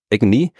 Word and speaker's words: AGNI: Aber wenn du ein I hinten dran hängst, dann kannst du es wie ein Wort aussprechen, nämlich als Agony oder Agony, was ja auch ein englisches Wort ist und wie übersetzt man Agony am besten auf Qual.